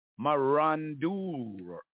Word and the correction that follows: marauders: M-A-R-A-U. Oh, it is marauders. I pronounced it wrong the first time there, right? Marauders. And that is spelled M-A-R-A-U-D-E-R-S. Marauders. White people are marauders. M-A-R-A-U-D-E-R-S.